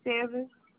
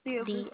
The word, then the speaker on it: Silvers: Davia Silvers. – Can you spell it for me? – D-A-V-I-A. Silvers. – D-A. – Ma'am. – You said D-A-V. – Yes, ma'am. D-A-V-I-A. Silvers. S-I-L-V-E-R-S.